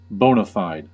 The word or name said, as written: bona fide